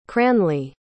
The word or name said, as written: cranly